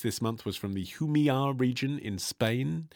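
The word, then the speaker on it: Jumilla: I learnt that's how you pronounce it from the free copy of Glug magazine they bundle inside. Otherwise, I would have called it Jamila, because that is how it is spelt.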